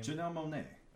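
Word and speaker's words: Janelle Monáe: Janelle Monae.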